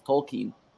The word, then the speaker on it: Tolkien: Makes no sense in the UK because his real pronunciation is Tolkien.